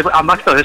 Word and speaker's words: Ambaxtos: He dicho que si la había pronunciado bien, estaba preguntándome Ambaxtos. – Eso es, correcto, sí, Ambaxtos, eso es.